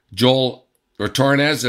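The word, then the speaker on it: Joël Retornaz: In the house, we're going to bring you two interviews, Kevin, that you did with a couple of guys from Italy, Joel Retornaz and, of course, Amos Mojaner. I pronounced those right, didn't I? – You did.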